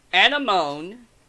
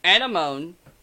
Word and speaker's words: anemone: Anemone. I'm going to say it again in case people thought I stumbled. Anemone.